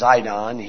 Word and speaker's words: Sidon: Verse 4, yea, and what have we to do with thee, O Tyre and Sidon? O Tyre and Sidon, sometimes pronounced Zidon here.